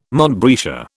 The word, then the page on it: montbretia: British English